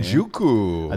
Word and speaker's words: Njoku: I'm going to take Njoku, however the fuck you say his name. Njoku.